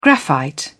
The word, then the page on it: graphite: British English